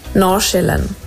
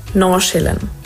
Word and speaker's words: Nordsjælland: Also eigentlich ist es gar nicht so eine Sache, aber man würde es so aussprechen. Nordsjælland. Das D sagst du eigentlich nicht und das andere ist so Sche. Nordsjælland. Ich hoffe, das hilft. – Das hilft auf jeden Fall. Nordsjælland.